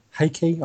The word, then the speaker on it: Hake: So yeah, it would be Archmaester Hake or Hakey. I guess it's Hake because there's no kind of accent on the E.